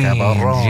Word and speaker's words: Caball'on: Am I saying this right? Caball'on. – Caball'on Valentin. – Caball'on. – Yes.